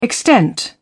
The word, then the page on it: extent: British English